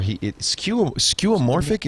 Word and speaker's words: skeuomorphic: Skeuomorphic? Is that how you say it? – Mm-hmm.